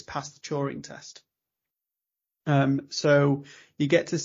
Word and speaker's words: Turing: Turing, is it? – Turing, yeah.